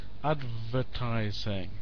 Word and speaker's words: advertising: So, for example, advertising, rather than adw. It's V.